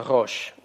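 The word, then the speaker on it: рош (ראש): Слово читается «рош», «голова».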